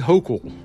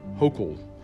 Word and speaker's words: Hochul: Oops, I, I, said it wrong. I'll admit it. Uh, it's pronounced Governor Kathy Hochul. Hochul. Uh, just so you know.